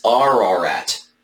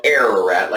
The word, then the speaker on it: Ararat: Now, I will say, in the Hebraic pronunciation is Ararat. Ararat. You know, we tend to say in English-speaking Western world, Ararat, like I've been doing the whole time.